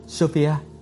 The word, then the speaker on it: Sophia: Sophia, chữ này phát âm như Sophia.